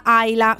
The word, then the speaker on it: Isla: Si stanno lasciando, stanno divorziando Sacha Baron Cohen e Isla Ayla Fisher, perché Isla in realtà si pronuncia Ayla,